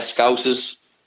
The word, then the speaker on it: Scousers: Is that how you, you pronounce it? – The yeah. Scousers? Yeah, Scousers.